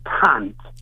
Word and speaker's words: cunt: If you're going to London, cunt is cant.